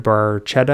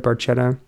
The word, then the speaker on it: Barchetta: Called Red Barchetta, I think is how you say that. Red Barchetta.